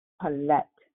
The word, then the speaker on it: palet: The Hebrew word for deliver is pronounced palet, comes from the strong concordance.